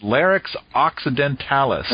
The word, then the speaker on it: Larix occidentalis: So it's Larix occidentalis. Did I pronounce that correctly? – I think so, yeah. – That a good? Larix occidentalis.